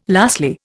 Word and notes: lastly: Indian

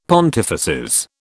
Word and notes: pontifices: British English

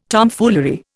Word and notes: Tomfoolery: Indian

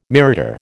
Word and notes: mirador: American English

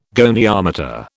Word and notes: goniometer: British English

